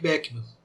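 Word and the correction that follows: Beeckman: o Bickman. A gente lembra, sempre quando fala em Bickman, lembra do mundo de Bickman, da TV Cultura, né? Mas provavelmente é Bickman mesmo a, a pronúncia, segundo o Google pronunciando em holandês, ou neerlandês, né?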